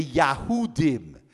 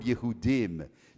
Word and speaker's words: Yahudim: The Russian Jews responded by referring to the German Jews as being the Yahudim, which is the German pronunciation of the word Yehudim, meaning Jews.